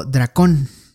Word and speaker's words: dracón: La palabra dragón viene del, latín draco, dracoins, que significa eh, reptil, pero también guardiano vigilante, que es un préstamo del griego dracón, con K y acento en la O, o dracontos, que significa serpiente y dragón, palabra que procede de la raíz del verbo dracón.